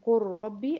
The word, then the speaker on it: قل ربي: لكن اما على بعض كده اقول قر ربي، اما تضغم دي في دي هقول قرة قرة، تنطق كذا قرة، لكن بتتكتب قل رب.